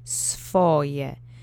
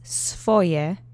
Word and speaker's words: swoje: Usually when you see a SW letter combination like this, it's not going to be pronounced sv, even though it should be. It's going to be softened because it's softened in speech. Sv is pretty hard to say together, so we've come to say swoje, sv. Let's practice swoje together three times. Accent is on the first syllable.